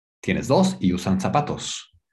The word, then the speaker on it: zapatos: Zapatos is spelled with a Z. Okay, in Spain, in España, they say za, like a TH sound. Zapatos. But you know, in Latin America, we just the Z is pronounced like an S, sa. So a lot of people say zapatos, z, but it's not zapatos; it's sa, like an S, zapatos.